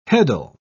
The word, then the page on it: heddle: British English